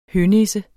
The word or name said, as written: hønisse